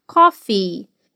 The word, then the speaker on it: coffee: Esse último som de vogal, o i, recebe bastante ênfase na fala, então fica coffee.